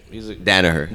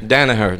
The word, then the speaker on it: Danaher: Of course, I always pronounce his name wrong. Dahmer. I mean, he's a good. – Danaher. – Danaher. Yeah. Danaher.